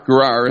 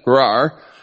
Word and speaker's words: Gerar: Gerar is a better way to pronounce that. Gerar.